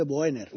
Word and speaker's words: Boehner: Boehner. – Sí. Vale, es que se escribe de una forma completamente diferente. – Entonces, sí. Boehner, y ya está, si lo dicen ellos, que son los que saben.